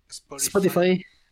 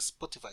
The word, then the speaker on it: Spotify: No se pronuncia Spotify, se pronuncia Spotify. – Spotify. – Como Sara y Sara.